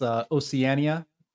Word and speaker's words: Oceania: Well, first of all, I just want to point out that it's Oceania. So the fact that you couldn't say that properly proves that you are not AI or AI assisted as a host.